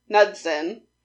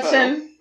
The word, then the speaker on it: Knudsen: Eric, I almost want to pronounce the K, I'm assuming the K is silent, Knudsen was the winner with his Slender Man Knudsen.